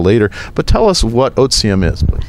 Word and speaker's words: Otivm: Otivm is the Latin pronunciation. So the TI is a Z, so Otivm is the right Latin pronunciation, because it's a Latin word, as Barbara will explain.